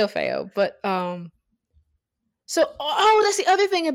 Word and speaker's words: Orfeo: Is that how it's pronounced? – Yeah, Orfeo. Yeah. – Because I was just gonna say Orpheus. – Yeah, you can say Orpheus.